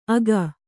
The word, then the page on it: ಅಗ: aga